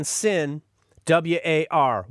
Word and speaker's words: Sinwar: Yahya is his first name, Y-A-H-Y-A, Yahya. His last name is spelled S-I-N, Sin, W-A-R, War.